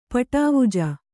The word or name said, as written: ಪಟಾವುಜ